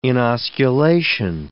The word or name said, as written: inosculation